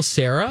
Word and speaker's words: Cera: Michael Cera.